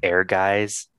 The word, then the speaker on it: Ehrgeiz: Did you guys ever play a game called Air Guys? – Oh, what happened in that? That sounds super familiar. – So it's spelled really weird. It's EHRGEIZ.